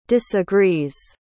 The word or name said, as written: disagrees